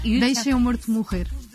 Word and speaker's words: Deixem o Morto Morrer: It's in Portuguese, it's very hard to pronounce Portuguese, but it's almost. – No, you deixem o morto morrer. We eat the vowels, so it's very hard.